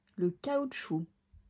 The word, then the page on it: Le caoutchouc: French